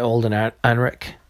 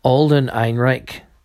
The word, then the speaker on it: Alden Ehrenreich: Alden Ehrenreich, I think is the way you pronounce it, acting ability.